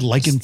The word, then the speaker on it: lichen: It's pronounced the same way, isn't it? L-I-C-H-E-N. – Yes. – As L-Y-C-A-N.